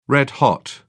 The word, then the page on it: red hot: British English